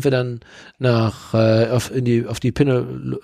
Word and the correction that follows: Peloponnes: äh, Peloponnes. – Penepol. Pele Peloponnes. Das konnte ich da schon da nicht aussprechen. Jetzt zum späten Abend wird es dann auch noch ein bisschen schwieriger. Aber wir sind dann östlich gefahren, also wir sind dann erstmal ähm, Peloponnes. Genau, richtig, genau.